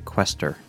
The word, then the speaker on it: Questor: Which, I thought it was Questor. – That's what I said. – That's what I thought. But in the movie, they keep on pronouncing it Questor.